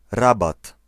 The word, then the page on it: rabatt: /raˈbat/